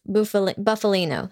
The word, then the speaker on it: Bufalino: Number four, mob member Frank Sheeran, the Irishman, called Hoffa on orders from Russell, Russell Buf, Bufalino.